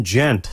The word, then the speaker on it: Ghent: Kim played flute and wrote the song with keyboardist West Byrd, B-Y-R-D. On tenor sax was Jasmine Gent, spelled G-H-E-N-T, with a soft G, like Studio Ghibli.